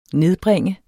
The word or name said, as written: nedbringe